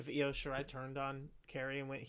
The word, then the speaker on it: Io Shirai: Then we get Kairi Sane and Los Shirai. How do you say her name again? – Io Shirai. – Io Shirai.